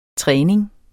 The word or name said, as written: træning